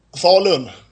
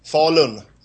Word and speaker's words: Falun: Falun. – Okay, that's how you pronounce it. – What does it say? How do you say it again? – Falun. – Oh, like fallen.